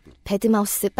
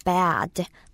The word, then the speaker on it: bad: 어, 배드마우스 빨리 가시면 안 되고요. 배아제, 배아제. 좀 어, 입을 크게 벌리시고 배, 아, 들을 빨리 한다고 생각하시면 발음이 잘 되실 거예요. 그래서 만약에 그냥 배드 이렇게 하게 되면 좀 침대처럼 들릴 수 있어요.